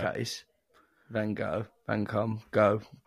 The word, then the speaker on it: Van Gogh: is it not Gough? – Vincent Van Gogh. – I thought the GH would be like cough. – No.